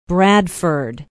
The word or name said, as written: bradford